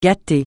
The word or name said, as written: gâter